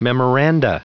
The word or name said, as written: memoranda